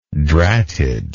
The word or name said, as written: dratted